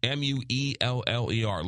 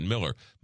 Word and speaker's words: Mueller: M U E L L E R. Looks like Mueller, but you pronounce it Miller.